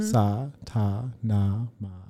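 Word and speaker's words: Sa Ta Na Ma: It's four syllables. Mm-hmm. Sa, ta, na, ma.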